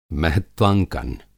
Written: महत्वांकन

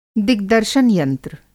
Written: दिग्दर्शन यंत्र

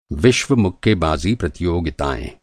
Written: प्रतियोगिता

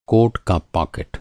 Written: पाकेट

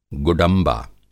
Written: गुडंबा

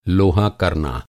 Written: लोहा

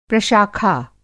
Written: प्रशाखा

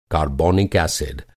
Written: कारबोनिक

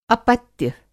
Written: अपत्य

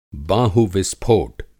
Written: बाहुविस्फोट

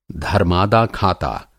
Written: धर्मादा-खाता

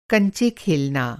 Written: कंचा

